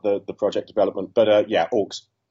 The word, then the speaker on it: AUX: And we've got you on to talk about your new film, AUX.